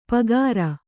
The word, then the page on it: ಪೂಗಾರ: Kannada